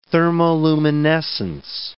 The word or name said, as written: thermoluminescence